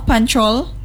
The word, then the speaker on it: Panchol: N C H O L Panchol.